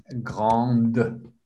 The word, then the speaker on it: grand: Silent D, grand. Here, it ends with a D. So the E is silent, but the D is pronounced here.